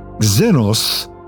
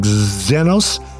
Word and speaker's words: xenos: Second comes xenos. A xenos, okay, if you want to say that right, see, it's not so much the Z like xenos, put a little bit of a K in there.